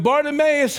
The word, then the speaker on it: Bartimaeus: Bartimaeus.